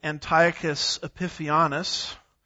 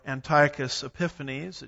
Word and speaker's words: Antiochus Epiphanes: A man named Antiochus Epiphanes, or Antiochus Epiphanes. It just depends on which syllable gets the emphasis, as one of my professors used to say.